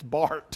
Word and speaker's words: Barth: It's spelled Barth, and I've mispronounced it for years. In seminary, they taught it. It's Barth. It doesn't look like Barth, but it is. Karl Barth.